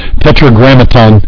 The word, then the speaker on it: Tetragrammaton: Pretty close. Tetragrammaton is what the word you were looking for.